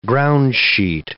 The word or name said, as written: groundsheet